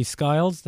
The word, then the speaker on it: Skiles: And I understand there were many people in the control tower, and of course, First Officer Jeffrey Skiles. Did I pronounce that correctly? – You did.